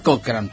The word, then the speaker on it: Cochrane: Así se escribe y se pronuncia Cochrane.